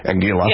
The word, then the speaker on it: Anguilla: Anguilla.